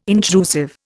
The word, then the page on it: intrusive: Indian